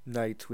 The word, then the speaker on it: knights: When you say knights, because knights is normally spelled K-N-I, you know? So anyone who doesn't know the English language might try to pronounce it Knights, you know, not realizing that it's just knights and the K is silent, and who knows why they decided to put that K there.